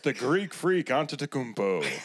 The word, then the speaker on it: Antetokounmpo: Now you have good nicknames like fucking like the Greek Freak, Giannis Antetokounmpo.